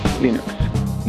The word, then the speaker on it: Linux: This is Linus Torvalds, and I pronounce Linux as Linux.